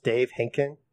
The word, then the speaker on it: Dave Henkin: It's pronounced Dave Henkin is the correct way.